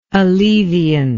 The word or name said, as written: alleviant